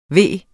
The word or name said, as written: ved